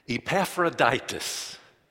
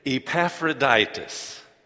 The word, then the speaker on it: Epaphroditus: We have Paul, then last week we studied about Timothy, and today we'll study about a man with a funny name, Epaphroditus. Can you say that with me? Epaphroditus. Now, when you say his name, you actually say the name of a Roman Greek goddess, Aphrodite.